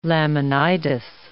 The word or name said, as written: laminitis